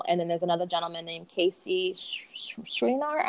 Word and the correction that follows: Schreiner: Yeah, he's the minority leader in the House, Casey Schreiner.